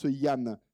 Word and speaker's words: Danh: Alors Yann, alors ça s'écrit D-A-N-H, mais ça se prononce Yann. – Oui, c'est la grande question de ma vie, vu que il se trouve que c'est mon prénom. Et je suis d'origine vietnamienne. Donc en fait, le D en vietnamien, écrit comme ça, se prononce comme le Y.